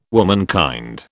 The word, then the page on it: womankind: British English